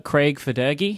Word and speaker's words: Federighi: I didn't pronounce it right. It's Craig Federighi.